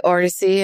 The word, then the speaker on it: Odissi: But Odissi dance is, well, I say Odissi and it's from Orissa, but the way you spell it is O-D-I-S-S-I.